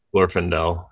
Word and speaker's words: Glorfindel: How do you say his name? Glorfindel. – Glorfindel. Yeah. – Glorfindel.